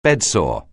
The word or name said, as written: bedsore